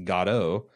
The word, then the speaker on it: Godot: Perhaps the most famous work of absurd theater is Samuel Beckett's Waiting for Godot, who she helpfully says is pronounced Godot, in which two characters wait for a man named Godot while their circumstances become increasingly bizarre.